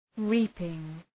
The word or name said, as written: reaping